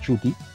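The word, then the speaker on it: Ncuti: Y yo viendo algunas este, cuestiones de, Doctor, eh, bueno, de la actuación de Chuti porque se pronuncia Chuti, Chuti como el rapero. ¿Qué Chuti. – Ajá, Chuti. No, la N es muda.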